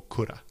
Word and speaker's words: coulda: Pero muchas veces lo decimos como coulda.